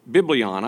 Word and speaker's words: biblion: That word is biblios. Biblion, I should pronounce that correctly. The word is biblion.